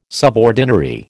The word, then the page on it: subordinary: American English